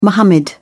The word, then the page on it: muhammad: British English